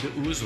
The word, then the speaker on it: The Ooze: L'album, c'est The Ooze, c'est comme ça qu'on prononce ? – The Ooze, oui.